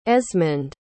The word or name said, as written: esmund